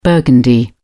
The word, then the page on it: burgundy: British English